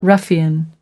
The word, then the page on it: ruffian: American English